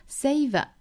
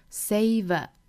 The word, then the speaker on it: savor: 首先我们来看单词发音，那第一个单词我们需要注意的是 savor 这个单词哈， savor 双元音 a, say, 后面呢是小刷音 a, savor, savor this moment, 后面 the moment when anything is possible, 最后的这个 possible 注意舌尖底上齿龈的尾音哈， possible, possible, possible.